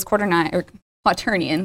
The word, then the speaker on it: quaternion: I totally called it quaternion. Quaternion. Always get made fun of for that. I don't know why. I look at it and I think it should be pronounced differently than it really is.